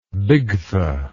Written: bigtha

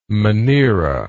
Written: monera

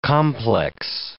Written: complex

